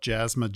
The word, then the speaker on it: Jaasma: If you want to just go to, my last name is spelled Jaasma, J-A-A-S-M-A.